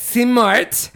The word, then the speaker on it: Simart: It's spelled S-I-M-A-R-T, that's Smart, and then the last name is Guy, spelled G-H-A-I.